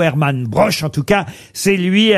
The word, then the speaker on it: Broch: Alors, je sais pas si on prononce broer ou broche. – Broche.